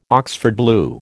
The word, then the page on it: Oxford blue: American English